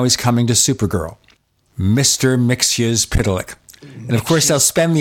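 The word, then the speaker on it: Mxyzptlk: And I was telling him how to pronounce the name Mr. Mixyzptlk. – Mixyzptlk. – Mixyzptlk. There are actually two versions in Wikipedia of this is the preferred version.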